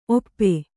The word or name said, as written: ಓಪು